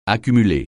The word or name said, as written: accumuler